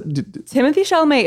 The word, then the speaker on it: Timothée Chalamet: Timothée Chalamet is how it's pronounced. I've watched interviews where he pronounced it.